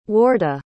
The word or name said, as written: wardah